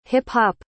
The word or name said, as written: hip-hop